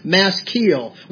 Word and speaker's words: maskil: The word maskil, the definition, is pronounced maskil, which actually means a hedge.